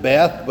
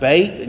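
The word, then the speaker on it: Beit (בית): You'd pronounce it Beth, but it's pronounced Beit.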